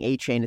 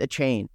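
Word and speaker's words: Achane: He came out and like got mad at people because they were mispronounced. They kept saying Achain. It's Achain.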